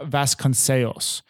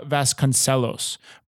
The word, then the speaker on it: Vasconcelos: The one way that I just said it is the correct way to say it, but I'm saying it in somewhat of a like a Spanish Portuguese accent. Nora Vasconcelos. But I didn't know how to say it before we looked it up. The way that I would have said it in like normal slang English would be just Nora Vasconcelos, but if you want to say it the very correct, proper way, it's Nora Vasconcelos.